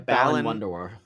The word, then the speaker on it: Balan Wonderworld: Oh, and it was called, I don't know how to pronounce it, Balan Wonderworld? – Yeah, Balan Wonderworld.